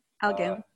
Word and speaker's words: Elgin: I always mispronounce it. – Elgin.